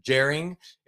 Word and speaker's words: Bjerring: The first thing is the, I think you pronounce it Jerring. It's BJERRING tournament.